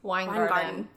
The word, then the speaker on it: Weingarten: How do you say your last name? – Weingarten. It's like drinking wine in a garden, but spelled very German.